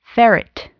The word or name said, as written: ferret